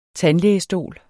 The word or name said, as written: tandlægestol